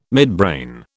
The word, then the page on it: midbrain: British English